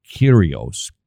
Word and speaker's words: Kyrgios: His name is Nick Kyrgios. It's got a G in there, but I don't think you pronounce it.